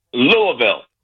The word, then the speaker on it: Louisville: Is the correct pronunciation Louisville or Louisville? – Louisville. – Louisville. Okay, thank you.